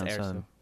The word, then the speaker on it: Erso: Rose, you know, Tico, Rey, or Jyn Erso? Is that how you pronounce it? – Erso. – I can't remember. – It's Erso.